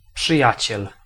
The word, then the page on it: przyjaciel: /pʂɨˈjat͡ɕɛl/